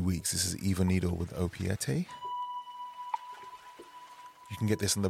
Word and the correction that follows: Opiates: thank you, Hella Souche, for the pronunciation correction. Opiates.